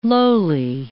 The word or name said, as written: lowly